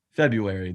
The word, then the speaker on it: February: February. The R is a silent R. It's one of the rare silent R's.